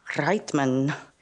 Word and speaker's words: Reitman: I guess if you pronounce it like German, it's Reitman.